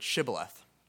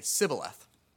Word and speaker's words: shibboleth: And when he wanted to find out if someone was on his side, he would ask them to say the word shibboleth. Now, the people he was fighting against couldn't pronounce that word exactly right, and who can blame them? So they would say sibboleth.